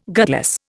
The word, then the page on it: gutless: Indian